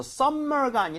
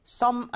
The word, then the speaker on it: summer: 그래서 summer가 아니라 summer로 발음했습니다. 제가 다시 한번 두 번 들려드릴 테니까 따라해 주세요. summer. sum 아닙니다. summer. summer.